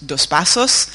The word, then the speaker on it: Dos Passos: Donc, tout d'abord, euh, si vous remarquez la sonorité de son nom de famille, hein, alors en bon américain ça se prononce Dos Passos, euh, mais c'est un nom qui est d'origine portugaise.